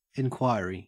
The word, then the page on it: enquiry: Australian English